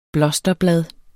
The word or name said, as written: blosterblad